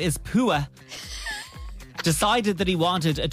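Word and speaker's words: Poo-Ah: So it's spelled P-O-O hyphen A-H. – Yes. – Pua. Around the town of Orleans over the weekend. So there's obviously an Orleans and a New Orleans. Mm-hmm. The Orleans Police Department posted online to say Pua had ambushed his pen.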